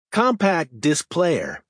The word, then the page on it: compact disc player: American English